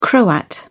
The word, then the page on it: croat: British English